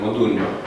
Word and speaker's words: Modugno: Modugno, se scrie Modugno, se pronunță Modugno, Cutugno, da? – Toată. – Se scrie cu G, N, dar nu se pronunță așa.